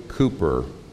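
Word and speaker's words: Cowper: One day, William Cooper, and that is how you pronounce it, though it's not spelled that way, but William Cooper showed up in town.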